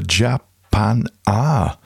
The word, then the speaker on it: Japanah: And then from 2023, from the Japan Ah EP, thus pronounced because of the way it was spelled, J-A-P-A-N-A-H, released by Mark Mack in the track Tokyo Nightlife.